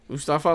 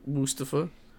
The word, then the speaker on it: Mustafa: Mustafa Ali has put up with the. Well, I'm sorry, not Mustafa. Mustafa, my bad. You gotta say that right.